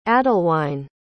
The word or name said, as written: adalwine